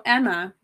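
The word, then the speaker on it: Aemma: Okay, so Rhaenyra's mom, her name is Emma, but it's not spelled the way you think. It's spelled A-E-M-F-A, I think.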